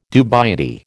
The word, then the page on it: dubiety: American English